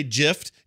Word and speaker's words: GIF: So if that is the acronym, graphics G, hard G. You don't say giraffe. You say giraffe because that's a different kind of usage. You don't say gift. You say gift. It's a freaking G. It's a hard G. It's GIF. It's always GIF. It will always be GIF.